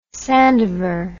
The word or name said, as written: sandever